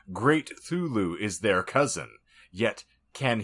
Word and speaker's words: Cthulhu: So it is pronounced Thulu. No, that's how I pronounce it, because the way that he phonetically wrote out the pronunciation when I've looked up Lovecraft's description, you can't pronounce it. It's intended to be an alien tongue.